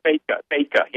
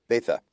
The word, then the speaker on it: Bethge: Bethge, yeah. – Bethge.